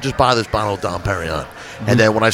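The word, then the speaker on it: Dom Pérignon: Dom Perignon.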